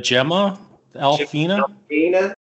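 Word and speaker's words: Jemaa el-Fnaa: Was it the Gemma Alfina? – That's it. – Is that Jim Alfina? – Yeah.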